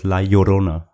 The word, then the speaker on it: La Llorona: Ja, geschrieben ist La Llorona, also mit Doppel-L. Aber die Spanier, die Mexikaner sprechen das La Llorona, also genau. Mit J und einem rollenden R. – Ich werde wahrscheinlich La Llorona sagen, bitte steinigt mich nicht dafür.